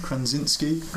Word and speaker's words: Krasinski: This is John Krasinski's Is that how you say his name? – Krasinski.